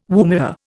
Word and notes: woomera: Indian

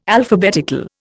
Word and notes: Alphabetical: Indian

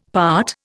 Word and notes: part: Indian

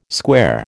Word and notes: square: American English

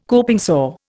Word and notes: coping saw: Indian

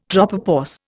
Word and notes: tropopause: Indian

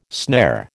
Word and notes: snare: American English

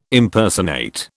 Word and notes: Impersonate: British English

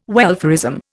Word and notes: welfarism: Indian

